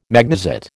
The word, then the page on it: magnesite: American English